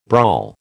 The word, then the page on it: brawl: American English